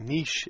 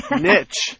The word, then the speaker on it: niche: And now dictionaries give two pronunciations. – And you write that niche is now forcing out niche.